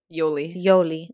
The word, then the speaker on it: Yoli: But then when they were with Jolie, it's like they looked like they were having a good time. – It's Yoli, babe. – I always say her name wrong. What is it? – Yoli.